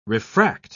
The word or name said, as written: refract